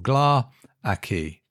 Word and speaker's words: Gla’aki: but they have Gla with the emphasis on the first syllable, Gla’aki, Gla’aki. – In the story, as we'll encounter a bit later, one of the characters makes reference to how it's pronounced, and it spells it phonetically in the story as G-L-A-R-K-Y, Gla’aki.